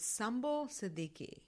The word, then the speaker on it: Sumbul Siddiqui: You know, it's Sumbul Siddiqui.